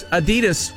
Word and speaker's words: Adidas: Americans pronounce Adidas wrong. It's not Adidas. It's supposed to be Adidas. – That's right. – Yes, not Adidas. It's Adidas.